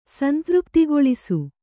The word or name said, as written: ಸಂತೃಪ್ತಿಗೊಳಿಸು